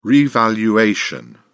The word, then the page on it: revaluation: British English